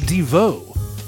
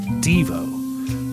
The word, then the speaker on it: Devo: And yes, it is pronounced Devo and not Devo, because it stands for De-Evolution.